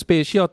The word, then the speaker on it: Spatial: ออกเสียง Spatial แต่สะกดเป็น S-P-A-T-I-A-L นะครับ